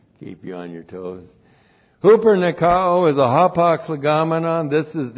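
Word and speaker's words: huper nikao (ὑπερνικάω): In all these things, and then the verb huper nikao. Huper nikao. H-U-P-E-R-N-I-K-A, long O.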